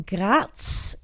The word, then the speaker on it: Graz: They're from Graz. That's how you pronounce it.